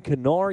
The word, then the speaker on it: Kinnear: But the real story for this one for Wisconsin is Brandon Kinnear. Yes, you pronounce the K.